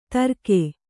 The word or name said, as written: ತರ್ಕೆ